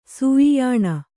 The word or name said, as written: ಸೂಯಿಯಾಣ